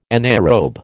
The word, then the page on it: anaerobe: American English